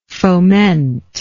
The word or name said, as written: fomenter